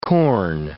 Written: corn

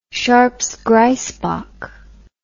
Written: sharpes grysbok

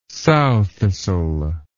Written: sowthistle

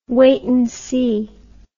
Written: wait and see

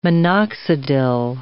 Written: minoxidil

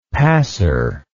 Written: passer

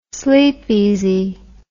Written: Sleepeezee

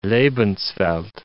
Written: Lebenswelt

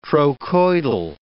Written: trochoidal

